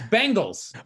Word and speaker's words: Bengals: Isn't it Bengals? There's like three different pronunciations. I don't even know how you just said it. My buddy says he says Bengals like the girl band. – Yeah. – It's Bengals.